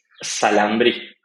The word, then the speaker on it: Xalambrí: So, what is, how do you say that? – It's Xalambrí. – Xalambrí. Okay, cool.